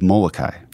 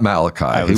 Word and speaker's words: Molokai: or St. Damien of Molokai. – Yes. Very good pronunciation. – Not Malachi. – He was the prophet. – Okay. – Molokai is the island, the Hawaiian island.